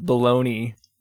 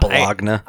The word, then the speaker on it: Bologna: It's not Bologna. – I actually had to go look up the pronunciation of the city, which is Bologna. – Okay. – But I think some people actually do pronounce it Bologna, and we definitely do here in America because we're...